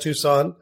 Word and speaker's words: Tucson: Max is a practicing dentist in Tucson, Arizona, pronounced Tucson.